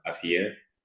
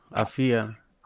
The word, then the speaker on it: Afia: This is how you say it, Afia. – Yeah, Afia.